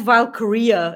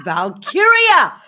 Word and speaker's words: Valkyria: He says Valkyria, and I'm like, it's Valkyria. What? It's not that hard.